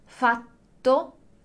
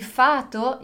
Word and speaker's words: fatto: Então, se você falar il fato e non il fatto, è ovvio che una persona riesce a comprendere.